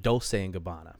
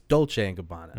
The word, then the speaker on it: Dolce and Gabbana: And she pronounced it Dolce and Gabbana. I'm not making fun of her. I love my mother. – Okay. – But I correct her. I said it's Dolce and Gabbana.